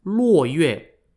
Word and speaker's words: Luo Yue: The earliest Vietnamese people were known in Vietnamese as Lac Viet, spelt L-A-C-V-I-E-T, or pronounced in Chinese as Luo Yue, spelt L-U-O-Y-U-E.